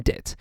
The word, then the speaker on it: debt: and of course we don't pronounce the B in debt.